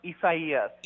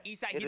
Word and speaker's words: Isaias: Yes, this is Tropical Storm Isaias. – Isaias, he does it well.